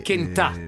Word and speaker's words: Kentà: Sì, Kentà, l'accento è sulla finale.